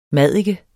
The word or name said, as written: maddike